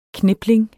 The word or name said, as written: knipling